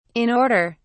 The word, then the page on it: in order: British English